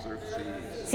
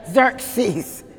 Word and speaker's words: Xerxes: Xerxes. – See, that's good to say because it starts with an X, and you'd be like, how do I say that? Okay, Xerxes, all right?